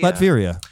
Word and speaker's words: Latveria: How do you say that? Latveria? Latvia? – Latveria.